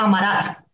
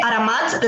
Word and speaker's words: Aramat: Sí, la, gente se confunde bastante y muchas veces lo he visto en redes sociales como Amarat. Realmente Aramat, la raíz es Arabia, que es la inspiración principal para el sultanato, entonces bueno.